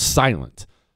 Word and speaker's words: SLNT: Silent is actually another acronym, S-L-N-T. It's pronounced Silent.